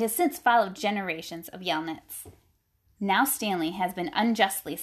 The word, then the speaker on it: Yelnats: So Stanley Yelantis, I don't know how to say his last name. – Yelnats. – Yelnats. Oh, that makes sense.